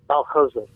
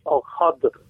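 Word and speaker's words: Al-Khadir: Now, a very important part of the Hadith transmission about this story is also the very name, Al-Khizr, which in more classical Arabic of the Hadith is pronounced Al-Khadr and voweled differently, so it's spelled K-H-A-D-I-R rather than the common K-H-I-D-R or K-H-I-Z-R.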